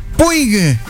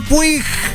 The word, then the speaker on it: Puig: Estamos con Arturo Puig. – Puig. – En Malos Pensamientos. – Acá en Uruguay me dicen Puch. – Puch. – Claro, también. – Porque así se pronuncia en Barcelona.